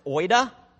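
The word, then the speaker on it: oida (οἶδα): They're pronounced oida and gnosko.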